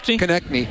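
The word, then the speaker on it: Konecny: Kopech, how do you say his name? Number 11? – That was the power play. – Yeah, well, yeah, what's is it? – Konechny.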